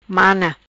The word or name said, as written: Mana